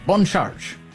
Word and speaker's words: Bon Charge: Bon Charge. That's the way you say it. A little French pronunciation.